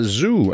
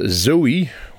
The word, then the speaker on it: Zoe: And uh, eh, I pronounce it right because the first time, a couple of uh, months ago, I. Uh, I picked out a song of your last CD and I said Zoe, which it shows if you write it, but it, it's called Zoo.